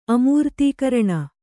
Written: ಅಮೃತಕಿರಣ

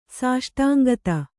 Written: ಸಾಷ್ಟಾಂಗತ

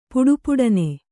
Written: ಪುಟಪುಟನೆ

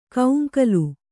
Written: ಕೌಂಕಲು

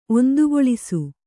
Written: ಒಂದುಗೊಳಿಸು

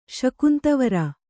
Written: ಶಕುಂತವರ

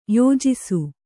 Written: ಯಜಿಸು

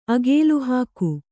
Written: ಅಕಲುಹಾಕು

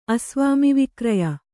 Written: ಅಸ್ವಾಮಿವಿಕ್ರಯ

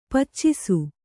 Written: ಪಾಚಿಸು

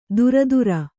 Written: ದುರದುರ

